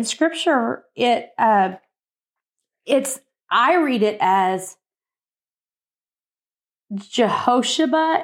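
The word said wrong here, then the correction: Jehoshabeath: and you say it's pronounced how? – It's Jehoshabeth. – Jehoshabeth.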